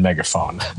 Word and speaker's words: megaphone: In megaphone, I think that the P and the H together make the F sound in megaphone.